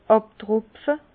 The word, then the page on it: àbtropfe: Bas-Rhin; Herrlisheim